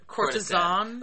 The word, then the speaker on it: courtesan: Yeah, and I could be wrong on some of these, but and I also think it's courtesan or courtesan, courtesan. – Like it's courtesan. Yeah, courtesan. Like it's. The lady of the court, kind of.